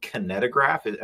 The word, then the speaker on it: kinetograph: Thomas Edison patents the, I want to say, kinetograph.